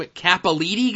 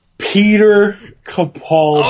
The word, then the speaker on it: Capaldi: Some Capolitti guy. Pete Capolitti. What's his pronunciation? – Peter Capaldi. – Oh, is that how you pronounce that? – Yeah.